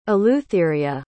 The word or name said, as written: eleutheria